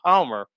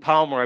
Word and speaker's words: Palmer: You got to love a pronunciation of someone who actually puts the L in Palmer. Yeah, you don't hear that all the time.